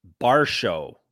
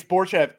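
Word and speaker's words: Borshchev: they were saying Vyacheslav Barsho, like B-A-R-S-H-O-W. – Like Dalton Barsho. Like Dalton Barsho. – Yeah. Like, yes. Barsho, like Darsho. Is it not Borshev? – It's Borshev.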